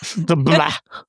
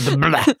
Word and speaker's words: DeBlois: Ich glaube, der heißt DeBlois. – DeBlois. – Der ist aus Quebec, Kanada. Da spricht man Französisch.